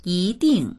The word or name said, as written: yi2ding4